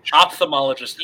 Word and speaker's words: ophthalmologist: Oh, well, it's O-P-H-T-H? Well, yeah, O-P-H-T-H. It's fucking wild. It's stupid. – But there's an F in there somehow. – Like, yeah, ophthalmologist. You don't pronounce the F sound. You just go ophthalmologist.